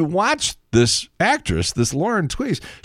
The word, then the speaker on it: Tewes: Tweez. T-E-W-E-S. And for some reason, she pronounced her name Tweez.